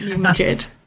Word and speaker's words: New Madrid: and not New Madrid? – So it's New Madrid because that's the way English speakers pronounced the town that was named for the Spanish capital.